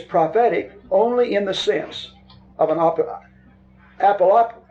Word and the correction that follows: apocalyptic: apocalyptic, I even lost the pronunciation of the word. Pronounce that. Right. I don't know why that lost me. Apocalyptic, apocalyptic, okay.